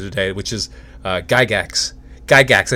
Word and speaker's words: Gygax: I think I said that right. I was saying Gigax before I knew how to say it, before I got on this interview, and he said the name correctly. Um, but hey, that's what I do.